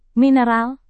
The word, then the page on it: mineral: Indonesian